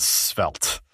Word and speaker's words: Svelte: So, Rich is, you know, one of the big projects you work on is called Svelte. And I've confirmed with Rich that I pronounced it correctly, too. It's not Svelte or Svelte or any kind of weird anything.